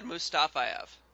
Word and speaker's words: Mustafayev: The guy I like is, I'm going to butcher his name here, but Magomed Mustafa, that guy, Mustafa, whatever. Mm-hmm. The Russian? – Magomed Mustafayev. – Thank you.